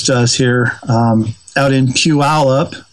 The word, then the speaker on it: Puyallup: I say that slowly so people who aren't from here can pronounce it correctly. – Pull you up. That's what it always looked like to me. But yeah, I had to have my son-in-law, Eric, had to teach me how to say it right.